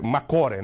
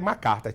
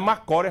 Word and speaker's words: MacArthur: É Macore, né? Macore. Né? Mas se o brasileiro estava vendo lá, ele aquele negócio que é Macarta. Tipo, o que é isso aí? Macarta. Mas a pronúncia certa é, é Macore.